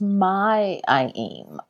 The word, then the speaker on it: May: And then I think listeners might also like to hear about, is it May or my IEM's role in this publication? – It's my IEM. But so it's like it's pronounced M-A-I, even though it's spelled with a M-A-Y.